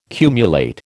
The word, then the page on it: cumulate: American English